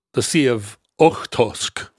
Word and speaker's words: Okhotsk: Okhotsk. We were like, we are so much closer to the Sea of Okhotsk.